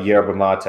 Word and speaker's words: yerba mate: I'm going to have to try some yerba mate.